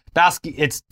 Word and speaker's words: Basquiat: One of the late 20th century's most famous examples of this was Jean-Michel Basquiat. I know I'm pronouncing Basquiat. – I think it's even different than that. It's a little more harsh on the T.